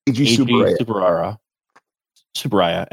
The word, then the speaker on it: Eiji Tsuburaya: This franchise began through the like very important, like, I'm gonna butcher his name, but Eiji Tsuburaya.